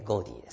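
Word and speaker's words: gaudy: でまあ、でも、まあ、スペリングはガウディですけどね。まあ、読み方は、ゴーディですけどね。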